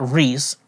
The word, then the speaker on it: Rhys: And so at the end of the first part, like, you learn that Reese, R-H-Y-S, Reese, that's how you say his name. It could have been R-E-E-S-E.